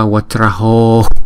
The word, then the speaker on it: Vajra: Chữ Vajra là chúng ta đọc hai âm Vajra. Chữ J này chúng ta đọc là J, Jra, Vajra.